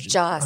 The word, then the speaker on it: Joss: His name is Joss. – I'm sorry, Joss Whedon. What? – Joss. – Joss? – J's or Y's. – Yeah, for some reason, I always go with the Nordic pronunciation.